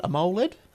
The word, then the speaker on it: AMOLED: AMOLED? – AMOLED LED.